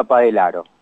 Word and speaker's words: Padellaro: Es un, es un nombre italiano. Se escribe Padellaro con doble L, pero se pronuncia Padelaro.